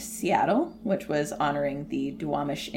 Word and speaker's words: Duwamish: And it was the traditional territory of Coast Salish, which is specifically the Suquamish and the Duwamish tribes.